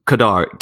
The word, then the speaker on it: Qadar: Qadar, Q A D R is what you normally hear. The pronunciation is a Q A D A R for it.